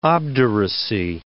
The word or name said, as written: obduracy